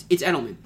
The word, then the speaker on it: Edelman: I don't know why I can't say his name. It's Edelman.